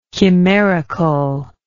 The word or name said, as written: chimericalness